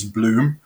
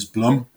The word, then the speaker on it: Blum: Really, the official pronunciation is Bloom. Um, many many people out there know us as Blum,